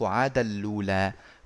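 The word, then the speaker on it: عادًا لُّولَىٰ: نقل حركة الهمزة إلى اللام ذهاب الهمزة طبعا وبعدين دخول النون الساكنة من عادا في اللام المضمومة فيصير النطق عادا لولا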